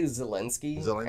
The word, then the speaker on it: Zelensky: I say Zelensky.